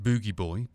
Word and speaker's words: Booji: Um, it's pronounced Boogie Boy, but the strange spelling B O O J I resulted when the band were using set.